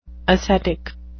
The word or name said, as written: ascetic